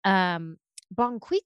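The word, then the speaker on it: Bon Qui Qui: Bon Quiqui.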